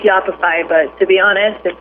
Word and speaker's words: Yoppify: She's the founder and CMO of a company called Yoppify. Am I saying that right? – You got it.